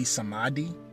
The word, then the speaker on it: Samedi: But his name also can be pronounced Bawen with a W, B-A-W, Bawen Samedi. And Samedi can be Samadi, because the word Samedi in French is Saturday.